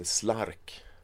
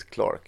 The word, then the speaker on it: Clark: Man hette Lawrence och jag hörde om någon som hette Slark. Och det var så Clark, fast de visste inte att det uttalades Clark utan de hette Slark.